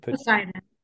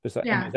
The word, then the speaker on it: Poseidon: Poseidon. – Poseidon.